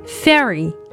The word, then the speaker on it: fairy: 那么在美音中呢，我们是直接读成了小口型的 a fairy。